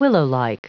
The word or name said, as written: willowlike